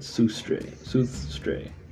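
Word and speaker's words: Suðri: Which are actually pronounced Istre, Vestre, Norstre, and Soothstre.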